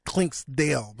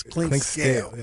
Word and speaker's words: Clinkscales: You know, I've been pronouncing your name wrong all these years. Clinksdale, but it's Clinkscales.